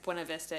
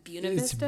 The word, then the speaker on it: Buena Vista: I always wonder how to say it, because Colorado has a lot of different ways of saying things, like Buena Vista is Buena Vista. – It's Buena Vista.